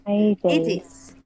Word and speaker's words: Hades: Hades. Rhymes with Hercules.